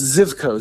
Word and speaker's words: Zivko: How did you pronounce that? It's not... You didn't say Zivco. I've always thought it was Zivco. – No, it's Zivco. Z-I-V-K-O. Zivco. – Okay. – Zivco. – I've been pronouncing it wrong forever.